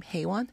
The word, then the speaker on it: Hae-won: It was a movie, and there was a Korean character, and she was trying to teach this American woman how to pronounce her name, Hae-won, and she just couldn't do the hey.